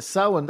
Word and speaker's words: Samhain: So there's the, I think it's pronounced Samhain Halloween Festival at Culturelawn. And it's full of an eclectic mix of music and cultural events for both English and Irish. – Yeah, that's good. Samhain, that's right.